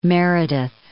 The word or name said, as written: Meredith